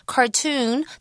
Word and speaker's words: cartoon: OK, 好。那刚刚那整句呢就说哇这部卡通的节目跟随着哇有两个角色就是 Finn and Jake。那我们中文翻成很可爱的名字、哦、阿宝和老皮哦的什么的 Adventures, 就是冒险旅程就展开啦。那其中呢我就看到我们的 Word Power Word, 也就是 Cartoon。Cartoon, 它的拼法是 C-A-R-T-O-O-N. Now be careful in Chinese, cartoon, 它的意思就是卡通嘛。那英文呢它的发音 because of the two O's, it has that oo sound.